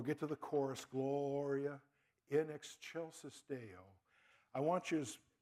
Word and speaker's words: in excelsis: And by the way, something I only learned a few years ago, it's spelled like in excelsis, like it's Excel spreadsheet. It's actually pronounced in excelsis, like a CH.